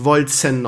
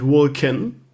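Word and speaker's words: Wolcen: Übrigens, ich habe gelernt, Die Entwickler haben gesagt, es wird wirklich Volzen ausgesprochen und nicht Vulcan.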